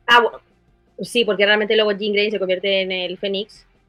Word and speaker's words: Jean Grey: Félix también nos recuerda que Jean Grey, por alguna razón, en el intro le decían Jane. Ah. Jane. – ¿Cómo se dice si no? – Jean Grey.